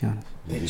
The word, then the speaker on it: Giannis: G is silent. Giannis. It's Giannis.